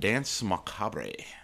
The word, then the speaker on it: Danse Macabre: But anyway, the next podcast we're going to be talking about is it ties in with Halloween, Freemasonry, Memento Mori, and Danse Macabre. It's pronounced dance. – Dance. – Danse Macabre.